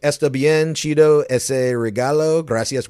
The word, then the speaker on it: chido: Today's word is Cheeto. Cheeto. – Oh, like a Cheeto? Chips? – No, or Cheetah. Or that too. Yeah, it's spelled C-H-I-D-O.